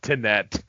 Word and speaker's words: Tenet: Yes, Tenet. Did we figure out that's actually how it's pronounced? – I think so, because it's not Tenant, because there's no N. So Tenet, right. Or Tent.